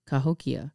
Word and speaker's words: Cahokia: I'm not sure how big that is, but Kahamuk, am I saying it right? Cahokia. See, I told you there was pyramids in Illinois, bruh.